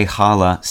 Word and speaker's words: challah: Today, challah, C-H-A-L-L-A-H, and the anglicized plural with an s are the variants usually encountered by English speakers.